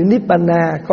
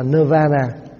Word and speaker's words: Nibbana: Mà chữ Nibbana có khi mình đọc là Nirvana. Nibbana là mình đọc theo âm tiếng Phạn, Nirvana là mình đọc theo âm tiếng tiếng tiếng Bắc Phạn, tiếng Sanskrit. Thì chữ nơ là gì?